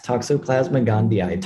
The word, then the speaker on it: Toxoplasma gondii: Yes, Toxoplasma gondii.